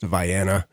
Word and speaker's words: Vienna: The town of Vienna is pronounced Vienna. That is Southern Illinois for you in a nutshell.